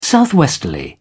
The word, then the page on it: southwesterly: British English